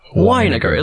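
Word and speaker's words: Winegar: Weiniger. It looks like vinegar. – Yes. – Which I understand. People would want to say vinegar.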